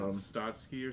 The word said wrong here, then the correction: Trotsky: But Trotsky.